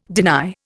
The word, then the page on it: deny: Indian